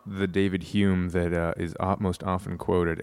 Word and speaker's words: Hume: I think what I like so much about John Ciardi is, funnily enough, something I actually like about David Hume, who I've heard actually is supposed to be pronounced like something like home or whom, maybe with more of a Scottish whom. Hume, I don't know.